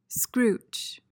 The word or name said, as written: scrooch